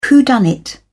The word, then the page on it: whodunnit: British English